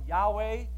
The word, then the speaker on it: Yahweh: One was, it was a public demonstration to the Canaanites that Jehovah, a covenant-making God, and I use the word Jehovah, it's not translated that way, but it's actually pronounced probably Yahweh, but the Jews would never say that, and so I don't want to say it either, so it's Jehovah.